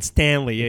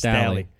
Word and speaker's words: Staley: It's not Stanley. It's Stally.